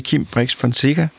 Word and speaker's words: Kim Brix Fonseca: Som i dag handler om kampvogn, og min næste gæst, det er Kim Brix Fonseca. Var det rigtigt udtalt? Det er fuldstændig korrekt.